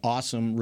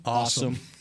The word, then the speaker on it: Asim: They had a lot of guys that it's kind of working multiple places, like the Awesome Richards, the tackle in the fifth round. – What's his name? – Awesome. It's not a C. – A-S-I-M, but it's pronounced awesome.